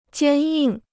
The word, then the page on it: 坚硬: jiān yìng